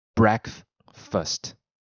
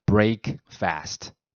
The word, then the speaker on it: breakfast: 就是晚餐后一直空腹到隔天早上所吃的第一餐嘛，所以才叫 breakfast。 OK，那以前当然是念作 break fast。